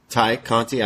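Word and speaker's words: Tay Conti: Ty Conti. I always say Tay because that's how I spell it in my notes. Ty Conti.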